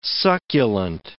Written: succulent

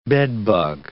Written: bedbug

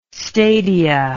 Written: stadia

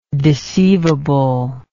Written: deceivably

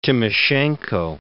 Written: Timoshenko

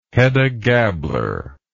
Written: Hedda Gabler

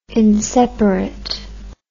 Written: inseparate

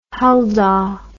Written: Huldah

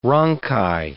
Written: rhonchi

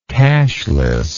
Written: cashlessness

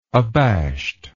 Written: abashedness

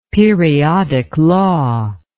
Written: periodic law

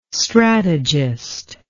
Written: strategist

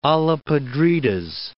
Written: olla podridas

